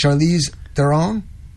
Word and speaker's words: Charlize Theron: Charlize, is that how you say it? Charlize Theron? – Yes.